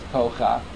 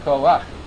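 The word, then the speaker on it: Koach (כֹּחַ): The correct pronunciation, like Koach, is not pronounced when you have a word that ends with a Ches, with a Padot, so it's not pronounced Kocha, it's pronounced Koach.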